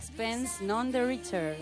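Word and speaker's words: Sixpence None the Richer: Sixpence Not on the Richer. O algo así. ¿Lo he dicho bien, hija? – Bastante bien. – Bastante bien. – Sí. – A ver, ¿cómo lo dirías tú? Sixpence. A ver, la anglófona. – Sixpence, none the richer.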